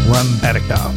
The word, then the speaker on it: Rembetika: I finally figured out how to say it. Rembetika.